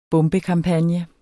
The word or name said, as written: bombekampagne